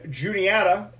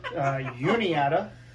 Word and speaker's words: Juniata: Hartwick and Juniata, or as you tried to pronounce it, Uniata, because we threw some Spanish in there, which was cool.